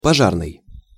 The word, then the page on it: pozarnyj: Russian